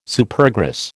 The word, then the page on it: Supergrass: American English